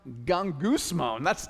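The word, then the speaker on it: gung-goose-moan (γογγυσμός): Pronounced gung-goose-moan. We just got to say that together because it's so weird.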